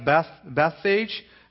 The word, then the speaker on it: Bethphage: When Jesus cursed the fig tree at Bethphage, that's how we English speakers pronounce it, it was Bethphage.